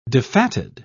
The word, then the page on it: defatted: British English